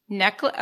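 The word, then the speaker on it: Necheles: Susan Neschles. Is that how you say it? – Necklace. – Okay. Thank you. Apologies for mispronouncing the name.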